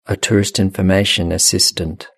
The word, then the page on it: a tourist information assistant: British English